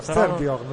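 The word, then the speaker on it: Styrbjörn: Sì, e no, il nome più che altro, come si pronuncia. Dovrebbe essere Sturbjorn. – Sturbjorn, però... sì, direi di sì. Ci può stare. – Sì. – Vabbè, il signor scritto, Oscarsson. – Scritto S-T-Y-R-B-J o con la dieresi R-N.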